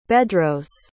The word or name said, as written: bedros